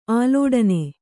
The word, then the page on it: ಆಲೋಡನೆ: ālōḍane